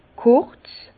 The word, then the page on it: kurz: Bas-Rhin; Herrlisheim